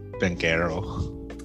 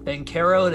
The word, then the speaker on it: Banchero: I'm going to just go with Banchero. – Banchero it is.